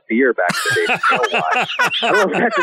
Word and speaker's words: alewife: Yeah, alewife.